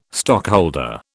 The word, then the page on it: stockholder: British English